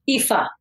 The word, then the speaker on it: Aoife: Aoife. – Aoife.